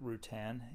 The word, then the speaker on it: Rutan: Well, he died on October 12th, 1997, when his experimental, I believe it's pronounced Rutan Long Easy Plane. Do you know how to pronounce that? – I think Rutan.